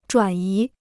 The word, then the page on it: 转移: zhuǎn yí